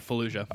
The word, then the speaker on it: Fallujah: The new, I'm probably going to pronounce it right, Fallujah? – Yeah, Fallujah.